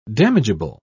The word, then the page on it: damageable: British English